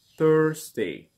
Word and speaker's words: Thursday: Thursday. Te saca un poquito la lengua.